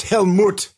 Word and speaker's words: Helmet: That's tough to follow Helmet. Jeez, I know. That was a fun one. – It's pronounced Helmut.